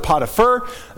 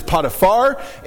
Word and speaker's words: Potiphar: And by the way, a lot of people pronounce it Potiphar. It seems like the more correct is Potiphar. And I'm probably going to, I grew up saying Potiphar, so you're probably going to hear me mess it up sometimes.